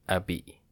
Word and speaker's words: abbé: Abbé, abbé, French pronunciation, abbé, abbess Noun.